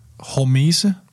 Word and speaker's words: hormese: En anden ting, et andet begreb, jeg synes var interessant i din bog, det var det her, nu ved jeg ikke, om jeg udtaler det rigtigt, hormese. – Ja, det er helt korrekt.